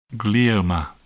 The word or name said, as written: glioma